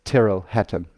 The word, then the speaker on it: Tyrrell Hatton: Ich habe dazu auch nochmal Eddie Pepperell konsultiert und gesagt, du hör mal zu, hm, ich weiß nicht, äh, wie, wie spricht man das denn aus? Ja? Und dann hat er mir eine Sprachnachricht geschickt und hat quasi gesagt, hey, um, ähm, it's, it's Tyrrell Hatton, mate. It's that simple.